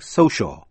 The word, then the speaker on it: social: Thứ hai là C phát âm là K trong những chữ như cat là con mèo, critic, C-R-I-T-I-C, critic là nhà phê bình. Thứ ba là C phát âm là sh trong tiếp vĩ ngữ C-I-A-L như trong chữ social là thuộc về xã hội. Shus, C-I-O-U-S như là vicious, độc ác, xấu.